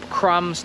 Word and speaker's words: crumbs: Bread to jest chleb, crumbs to są okruchy i widzisz, że to B w crumbs się nie wymawia.